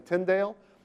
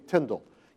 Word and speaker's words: Tyndale: And by the way, it's kind of traditional to pronounce his name Tyndale, but he sometimes spelled his name T-I-N-D-A-L-L. So most modern scholars say it should be pronounced to rhyme with spindle.